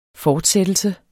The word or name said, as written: fortsættelse